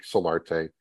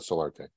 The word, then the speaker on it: Solarte: Just like I say it, because the pronunciations I've gotten over the years on my last name are just, how do you get solitaire out of Solarte? how do you make the jump to add the I and flip stuff around and it's solitaire all the time?